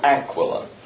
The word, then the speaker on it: Aquila: If you want to pronounce them like I do, it's Priscilla and Aquila. If you want to put the emphasis on the right syllable, it's Priscilla and Aquila. Take your choice.